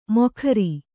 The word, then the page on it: ಮೊಖರಿ: Kannada